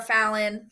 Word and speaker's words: Falen: I'm on TikTok and Facebook, Instagram and LinkedIn, Sarah Falen. Last name is spelled F-A-L-E-N.